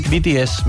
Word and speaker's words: BTS: BTS, me ha costado pronunciarlo.